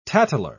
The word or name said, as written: tattler